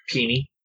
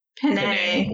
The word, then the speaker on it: penne: Peeny. – Penne.